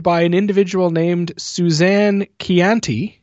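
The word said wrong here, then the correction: Ciani: Shit. It's not how you say it. No, there's no T there. – Oh, no. I listened to this a bunch of times just so I could get it right. Shiani. Shiani.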